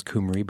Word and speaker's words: Cymru: I'm assured it's pronounced Cymru, but It's like a Toyota Camry, but with a U.